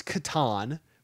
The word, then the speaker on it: Catan: I know it's Catan, but that sounds dumb.